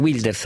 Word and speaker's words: Wilders: perché in Olanda ieri Geert Wilders, anzi Wilders a dire la verità, perché sennò faccio una pronuncia all'inglese,